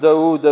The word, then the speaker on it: داود: داود داود تقرأ داود تكتب داود داود تكتب داود فاعل، لكن تنطق فاعول، ليس في كلام العرب كلمة تنطق فاعل، تكتب فاعل لكن تنطق داود، هذا ما ينطق على خلاف رسمه.